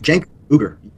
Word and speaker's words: Cenk Uygur: The first name is C-E-N-K. How do you pronounce Cenk? Cenk Uygur.